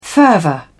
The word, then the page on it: fervour: British English